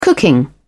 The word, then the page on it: cooking: British English